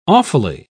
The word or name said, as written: awfully